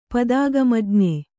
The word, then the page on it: ಪದಾಗಮಜ್ಞೆ: padāgamajñe